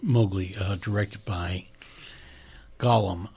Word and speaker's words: Gollum: Gollum. Gollum. – Mm-hmm. – That is how his name is said.